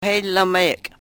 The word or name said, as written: helomeyek